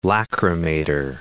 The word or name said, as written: lacrimator